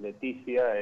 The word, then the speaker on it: Letizia: Sí, está, está bien pronunciado, es Leticia, es italiano, si querés le puedo poner una X, pero no hay ningún problema.